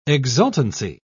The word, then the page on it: exultancy: British English